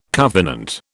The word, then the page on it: covenant: British English